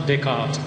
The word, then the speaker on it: Descartes: He's spelled D-E-S-C-A-R-T-E-S, but he's pronounced Descartes.